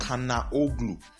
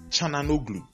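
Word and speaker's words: Çalhanoğlu: Then another one, AC Milan's number 10 is not Kanaoglu. Chananoglu. Chananoglu. That's the name.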